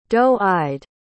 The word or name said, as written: doe-eyed